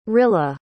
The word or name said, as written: rilla